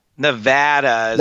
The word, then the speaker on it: Nevada: Nev- am I saying it right? Nevada? Nevada? – Uh, Nevada.